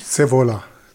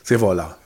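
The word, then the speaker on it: Scaevola: Muzius Saviola, richtig? – Sevola. – Sevola. – Ja, Sevola. – Italienisch, Lateinisch, was ist das? Das ist Italienisch, Muzius Sevola. Gaius Muzius Sevola.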